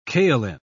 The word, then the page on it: kaoline: British English